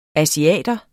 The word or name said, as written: ASIATERNE